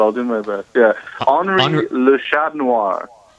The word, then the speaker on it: Henri: Think of it as Henri. It rhymes with ennui.